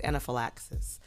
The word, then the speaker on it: anaphylaxis: I can't pronounce that. I'll spell it because I don't think I think it's anaphylaxis, but I'll spell it to be clear. A-N-A-P-H-Y-L-A-X is an X phone.